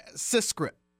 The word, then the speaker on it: CISCRP: Did I say it right there, or CISCRP? – Yes, you did, Cameron. CISCRP. Thank you.